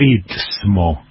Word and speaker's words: istmo: En realidad, estoy mirando bien, se llama istmo y se escribe primero con S y después con T. y yo sé que es más fácil decir Istmo que decir Istmo. Bueno, IST, y no ITS, ¿ok? No ITS.